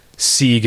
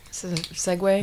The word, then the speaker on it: segue: Where I said, let's seeg into the next topic. – Mm. Segue? – Instead of segue. – Because it's spelled S-E-G-U-E.